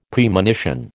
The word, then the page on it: Premonition: American English